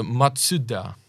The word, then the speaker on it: Mazda: značek aut a mě třeba pobavilo, že my říkáme Mazda. – Ano, a tam je to jo? jinak. – A ona je to Matsuda.